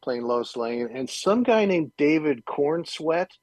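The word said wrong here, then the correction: Corenswet: Corn and sweat, I think is how you say it.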